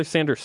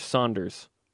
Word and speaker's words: Sanders: He was calling Taylor Sanders Saunders. – Taylor Saunders. – Or Saunder. I was like, are you serious? The best player in the game, and you don't know how to say his last name.